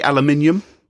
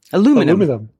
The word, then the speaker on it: aluminum: Or how do you say aluminium in America? – Aluminum.